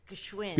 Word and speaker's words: Gschwind: And there's no vowel there. – No. No. – Right. I thought Crystal was crazy when she sent me that thing. I looked on the website. I couldn't find it. I was like, Gschwind. – When I spell it, I just say, just listen. Because everyone's looking for that vowel.